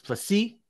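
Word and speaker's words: Du Plessis: DDP, Drusius Duplessis, is that better pronunciation? – I think he puts the emphasis on the last, like it's Plessis, you know, Dupree in WWE.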